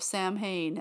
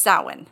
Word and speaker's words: Samhain: And he's talking about Halloween day where he's like, and on the pagan holiday of Samhain. And it's like, if you know anything about pagan holidays, you would know that is pronounced Samhain.